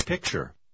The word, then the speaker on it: picture: сочетание T-U-R-E также может произноситься как chur. – Попробуйте прочитать номер 10. – I like this picture.